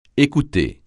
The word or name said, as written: écouter